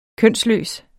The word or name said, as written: kønsløs